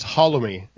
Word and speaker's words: Holloway: It's Holloway. It rhymes with follow me.